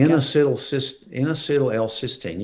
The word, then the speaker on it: N-acetyl L-cysteine: N-acetyl L-cysteine.